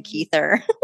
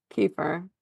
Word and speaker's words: Kiefer: It'd be hard to beat Kiefer Sutherland for that, though. – I really think Kiefer Sutherland was the right choice. – I'm going to start calling him Keither.